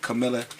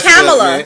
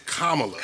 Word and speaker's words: Kamala: Let's do a great shout out to, you know, I think I said her name wrong last week, but Camilla Harris. Make sure I say it right this week. – Because our vice president is a black woman. – Still it's Kamala.